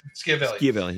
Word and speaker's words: Schiavelli: Vincent Schiavelli. – Schiavelli.